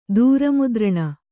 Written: ದೂರಮುದ್ರಣ

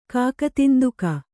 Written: ಕಾಕತಿಂದುಕ

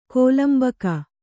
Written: ಕೋಲಂಬಕ

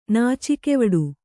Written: ನಾಚಿಕೆವಡು

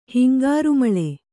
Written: ಹಿಂಗಾರುಮಳೆ

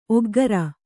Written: ಒಗ್ಗೊರೆ

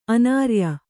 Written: ಅನಾರ್ಯೆ

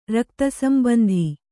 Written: ರಕ್ತಸಂಬಂಧ

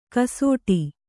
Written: ಗಸಟು

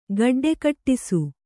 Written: ಗಡ್ಡೆಕಟ್ಟಿಸು

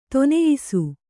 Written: ಧನ್ಯಾಸಿ

